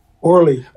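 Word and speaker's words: Orlie: Orly. – Orly. – L-I-E.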